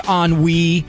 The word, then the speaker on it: ennui: It is ennui.